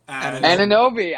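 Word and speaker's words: Anunoby: I don't know how to pronounce his last name. – Ananobi. I have him on my list. Keep going. – Ananobi as the best fit.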